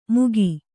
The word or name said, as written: ಮೂಕಿ